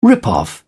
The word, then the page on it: rip off: British English